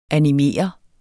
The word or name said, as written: animeret